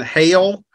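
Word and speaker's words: Hejl: Yeah, I believe I'm going to pronounce his name right, but Mason Hale, it's H-E-J-L.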